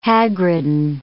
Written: hagridden

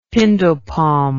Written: pindo palm

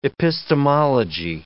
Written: epistemology

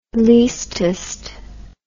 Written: leastest

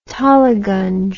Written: toliganj

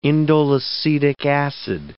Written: indoleacetic acid

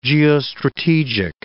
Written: geostrategic

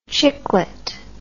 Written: chiclet

